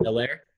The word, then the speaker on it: Hilaire: And also, he does the H is silent. So is it? Is it just Hilaire? – Yes. – Edwards-Hilaire.